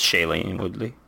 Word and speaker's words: Shailene Woodley: And I have a hard time my brain pronouncing her first name, but Shailene Woodley. – It has a cop out. It's Shailene Woodley.